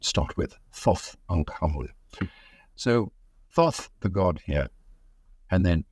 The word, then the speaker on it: Thoth: Thoth, of course, is in Greek pronunciation of tooth or tut.